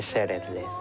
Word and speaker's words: Szeretlek: ¿Cómo se dice en húngaro te quiero? – Se dice Szeretlek.